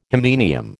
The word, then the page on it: hymenium: American English